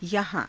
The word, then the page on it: yahaa~: Hindi